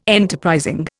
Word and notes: enterprising: Indian

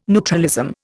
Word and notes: neutralism: Indian

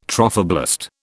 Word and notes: trophoblast: British English

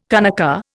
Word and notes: kanaka: Indian

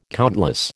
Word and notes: countless: American English

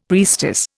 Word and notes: priestess: Indian

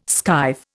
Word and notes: skive: Indian

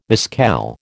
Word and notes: mescal: American English

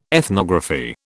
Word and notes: ethnography: British English

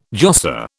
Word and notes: josser: British English

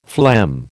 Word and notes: phlegm: American English

